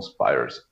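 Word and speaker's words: Spiers: Er sei an einer Überdosis Alkohol gestorben, war ein Top-Upologe, kannst mal reinsehen, könnt ihr alle mal reinsehen, Max Spires. Also so wie die Britney Spears nachgeschrieben, aber, aber ausgesprochen Spires und Max.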